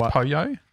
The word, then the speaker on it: pollo: I believe it's pronounced pollo. – Pollo. I believe. All right. – That could be wrong. – Polo.